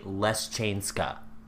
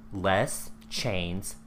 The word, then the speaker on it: Leszczyńska: Okay, so Polish names, like when you read Polish names, they're really complicated looking. But then whenever you hear it pronounced, it's like, oh, her name was Queen Marie Leszczyńska. Leszczyńska. – Because when you look at it, I'm just like... Consonants don't go together like that.